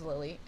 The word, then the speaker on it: Lily: My name is Lily, and it's not with a Y, it's with an I.